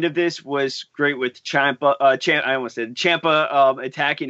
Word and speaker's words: Ciampa: It's Ciampa.